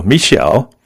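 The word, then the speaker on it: Me'Shell: Musician with a very hard name to pronounce, it's M-E-apostrophe-S-H-E-L-L, so Michelle, and then this is N-D-E-G-E-O-C-E-L-L-O.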